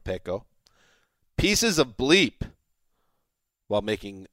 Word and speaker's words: Peko: During an event in London to promote the NFL's international series this season, Bengals defensive tackle Domata Peko. Is that how you pronounce it? Pico? – Dead on.